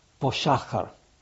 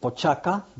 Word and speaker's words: Poschacher: In German or in Austria, it's called Poschacher, Firma Poschacher. The Spanish called it Pochaca because they couldn't pronounce sh, and it was called Comando Pochaca.